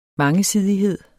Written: mangesidighed

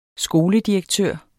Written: skoledirektør

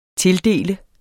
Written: tildele

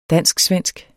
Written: dansk-svensk

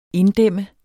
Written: inddæmme

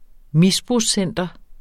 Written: misbrugscenteret

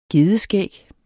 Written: gedeskæg

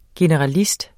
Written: GENERALIST

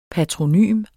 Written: patronym

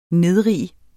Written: nedrig

